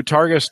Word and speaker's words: Targus: So, Brian had said in the chat, he had said he loves his Targus. Is that how you pronounce Targus.